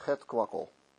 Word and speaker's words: het kwakkel: Alors la prononciation correcte en hollandais c'est het kwakkel.